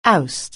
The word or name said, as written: oust